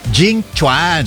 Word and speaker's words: Jing Chuan: Pag binasa mo, Jing Quan. Ito, Jing Chuan. – Sabi mo kasi pag binasa, Jing Quan. – Hindi, pag binasa mo ng pero okay. – ang pronunciation ay Jing Chuan.